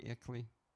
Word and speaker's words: Icley: I-C-L-E-Y, Ickley.